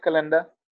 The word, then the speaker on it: Kalinda: I don't know if I'm pronouncing it correctly, but Kalinda? – Yes, Kalinda.